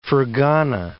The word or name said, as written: Fergana